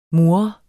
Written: mur

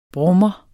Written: brummer

